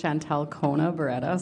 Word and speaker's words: Chantel Kona Barreda: My name is Chantel Kona Barreda. So my first name is C-H-A-N-T-E-L, middle name K-O-N-A, last name Barreda, B-A-R-R-E-D-A.